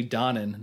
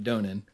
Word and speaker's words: Donen: I also learned in the commentary it's pronounced Stanley Donen, not Stanley Donen. – Oh. – So I've been saying that wrong for years now.